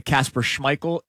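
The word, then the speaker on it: Kasper Schmeichel: By the way, Casper Schmeichel is the correct pronunciation. – Oh, proud of you. – Our in-house soccer expert, Sean Rodman, has confirmed it. – Casper Schmeichel? – And our overseas, Kieran, has confirmed it.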